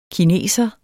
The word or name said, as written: kineser